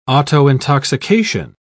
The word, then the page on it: autointoxication: British English